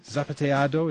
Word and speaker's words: Zapateado: Zapateado, is that how you pronounce it? – Yeah, very good pronunciation.